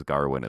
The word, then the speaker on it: Gawain: Okay, so I watched with subbies, and it's spelt Gawain, but they say it as Garwin in the movie.